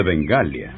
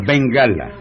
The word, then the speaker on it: Bengala: Un tigre de Bengalia. – En primer lugar, no se dice Bengalia. – ¿Y cómo se dice? Ah? – Se dice Bengala.